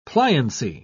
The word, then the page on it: pliancy: British English